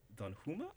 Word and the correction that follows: Danjuma: Am I pronouncing this right? From Netherlands? – Sure. Danjuma.